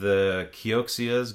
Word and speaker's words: Kioxia: Visit with Kioxia's got some people out there.